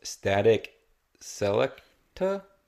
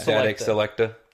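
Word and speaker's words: Statik Selektah: By Anoid, which is A-N-O-Y-D. – Okay. – And it also has Static Selecta, I guess is how you say his name. – Mm-hmm. – It's S-T-A-T-I-K. S-E-L-E-K-T-A-H. So, I think it's Selecta. I think.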